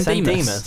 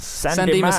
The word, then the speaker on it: San Dimas: How do you pronounce it? – San Dimas. San Dimas High School Football Rules.